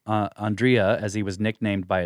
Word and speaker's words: Andrija: Andrija. Uh, Andrija yeah.